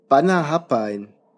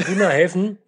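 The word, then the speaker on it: Bunnahabhain: Oh Gott, darf ich mal probieren, bevor du es richtig aussprichst? Bannerhappein. – Mhm, könnten wir so aussprechen, ja. – Könnte man, ja. – Bunnahevn. – Oh, fast. – Bunnahevn. – Ja.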